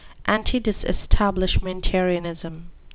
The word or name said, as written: antidisestablishmentarianism